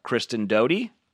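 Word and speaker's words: Kristen Doute: Already know it'll be a storyline for next season, but what can she share about the fallout with Kristen Doty? Am I saying that right? – Yeah.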